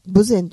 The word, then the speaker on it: 豊前: 私も知らなくてね、うん、これ豊中の豊に前と書いて何と読むのかしらと思ったら豊前と読むんですって。